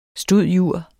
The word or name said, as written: stud.jur.